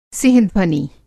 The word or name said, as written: सिंहध्वनि